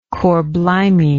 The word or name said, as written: cor blimey